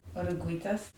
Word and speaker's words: Oruguitas: Oruguitas.